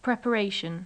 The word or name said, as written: preparation